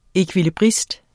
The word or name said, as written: ekvilibrist